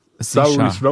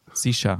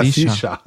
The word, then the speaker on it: Saoirse: Saoirse Ronan. – Sisha. – Was? – Ja, Sisha. So wie Sascha, aber mit so einem...